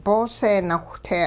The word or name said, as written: boose nawhte